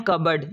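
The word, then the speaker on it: cupboard: Silent P है, कप बोर्ड, कप बोर्ड नहीं पढ़ना बल्कि इसको पढ़ना है कबर्ड।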